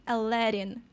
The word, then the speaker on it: Aladdin: Для нас это был Aladdin, а оказывается, в оригинале этого юношу зовут Aladdin, Aladdin. Привыкайте, именно так, ударение на второй слог.